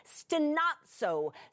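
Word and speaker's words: stenazo (στενάζω): The lexical term for it is stenazo.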